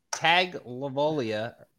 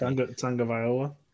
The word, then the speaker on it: Tagovailoa: Tua Tagovailoa. I did not pronounce that correctly. Correct. – Tagovailoa.